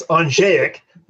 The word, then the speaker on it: Andrzejek: It's Anjayak, and hello from sunny California.